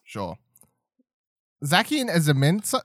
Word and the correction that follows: Zamazenta: Zamazenta. – Zamenzia? Zamazenta. you know what?